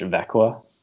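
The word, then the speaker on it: Växjö: it's spelled V-A-X-J-O, but they pronounce it in Swedish Vekwa, which don't ask me how they use their pronunciations, but I was going to spend Christmas with him and experience a real Christmas, Swedish Christmas with him and his Swedish wife.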